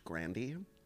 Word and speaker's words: Grande: do you know that her last name is actually pronounced Grandy? – That's so funny.